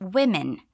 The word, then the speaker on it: women: Just so you know, the plural of woman is women. The singular is spelled with an A and the plural is spelled with an E. We don't add an S like we do in plural in Portuguese, okay? So here we're saying it's the day of all women, plural. There's an S sound at the end of women, but that's the S that indicates possession and not plural, okay?